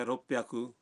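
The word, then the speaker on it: روپ پیاکو (六百): مثلاً 300 ہے سام پیاکو، 600 ہے روپ پیاکو، اور 800 کو کہتے ہیں ہاپ پیاکو.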